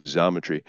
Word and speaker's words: Xometry: Tom in the Q&A asked us to look at XMTR, Xometry, pronounced with a Z, thanks to Eagle Eye calling the company. It is Xometry.